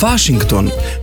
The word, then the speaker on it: Washington: Washington bomo izgovorili Washington, München pa München. Izjema so tuja zemljepisna imena, ki so v slovenskem kulturnem prostoru vse splošno znana in imamo zanje poslovenjena imena.